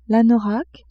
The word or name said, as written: anorak